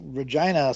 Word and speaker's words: Regina: Well, Regina smells like what it rhymes with, so that's there's one thing there.